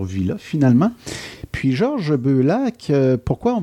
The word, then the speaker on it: Beullac: Beaulac. J'ai bien prononcé ça? – C'est très bien prononcé.